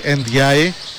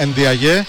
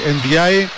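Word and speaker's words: Ndiaye: Να συμφωνήσουμε σιγά σιγά σε κάτι, γιατί θα έρθετε αύριο μεθαύριο και όλοι θα το λέτε όπως εγώ, ότι ο ίδιος ο Σενεγαλέζος το προφέρει Εντζάι, το επώνυμό του, άρα εγώ ακολουθώ τον Σενεγαλέζο. Αν δεν το ξέραμε, αν δεν το είχαμε δει το βίντεο, θα τον έλεγα ειλικρινά Ενδιαγέ, όπως τους ξέρουμε να τους λέμε τους Σενεγαλέζους, αλλά εφόσον το λέει Ενδιάι, εγώ θα τον λέω έτσι.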